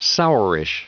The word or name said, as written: sourish